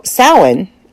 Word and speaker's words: Samhain: Okay, so Samhain, as it's pronounced, not Samhain. Not whatever else you want to call it. It's Samhain. Now, Irish Gaelic, it's Samhain.